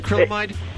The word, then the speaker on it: acrylamide: Am I pronouncing it correctly, Tina? Acrylamide? – Perfectly.